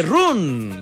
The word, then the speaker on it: run: Sí, pero no se dice run. Se dice ron. – ¿Cómo se dice? – No, run, no. Run. Incluso casi más con A.